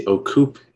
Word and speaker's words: Ocupe: It's not your fault. It's Casey Ocupe. It's Filipino.